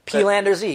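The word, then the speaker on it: Peelander-Z: Peelander Z, yeah.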